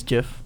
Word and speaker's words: GIF: You know, the correct one is GIF. – No, it's GIF. Get out of here with your soft G, man.